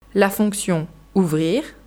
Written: la fonction ouvrir

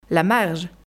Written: La marge

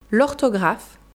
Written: l'orthographe

